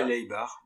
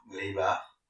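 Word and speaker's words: Laibach: Dans les années 80, vous avez fait une collaboration avec un groupe qui s'appelle Layback. je sais pas si je le prononce bien. – Oui, Laybar. – Laybar.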